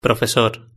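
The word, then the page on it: profesor: Spanish (Spain)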